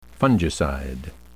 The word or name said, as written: fungicide